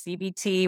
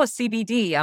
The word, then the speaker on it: CBT: I always say CBT, but I always have to say it's CBT with a T, not a D. Everyone thinks, oh, CBD. I'm like, well, we can talk about that another day in some TSH.